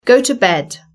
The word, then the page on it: go to bed: British English